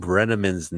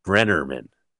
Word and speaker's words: Brenneman: She mispronounces Tom Brenneman's name. She throws in a second R. It's very hard. Brennerman. And this must be a beef for this guy because it's in the script. And the name is Brenneman. And she tries it. Brennerman.